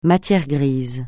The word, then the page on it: matière grise: francais